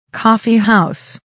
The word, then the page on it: coffeehouse: kɔ́ːfihàus; コーフィハウス